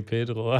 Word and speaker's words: Pedro: Pietro Lombardi. Wie sagt man das denn? – Nee, nee, Pedro.